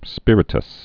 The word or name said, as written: spiritous